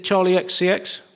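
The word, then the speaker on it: Charli XCX: Have I pronounced that right? Is it Charlie XCX? – Yeah.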